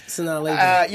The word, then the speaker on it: Sanaa Lathan: Yes, Sanaa Lathan.